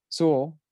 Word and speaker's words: Sewell: Uh, Jets Henai Suel. We had a hard time pronouncing his name earlier in the first one. – Sewell, thank you.